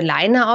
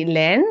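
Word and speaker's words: Laine: Und da hatte dann halt eine erzählt, es würde Leine ausgesprochen werden. – Leine weil wie das, Leinen los, ne? – Ja. ja. Und ich hätte das halt immer wie Len, wie Wolle ausgesprochen, aber es ist Finnisch für kurz.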